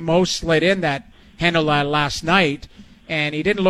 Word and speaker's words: Heinola: But then I watched them slide Ville Heinola, I believe. Is that how you say his name? – Heinola. Yeah.